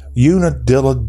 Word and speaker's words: Unadilla: That's spelled Unadilla. Unadilla, Georgia. I've seen that town for a long time, but never really heard it pronounced until I had to go, as we do here on the Y'all Show, we go out of our way for our customers and our listeners, and I found out how to properly pronounce Unadilla, Georgia.